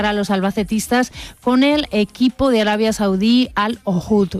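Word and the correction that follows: Al Kholood: No se lo he dicho bien, Al Kholoud.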